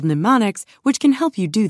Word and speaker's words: mnemonics: One way you can significantly improve your memory is by using memory techniques often referred to as mnemonics. The first M is silent.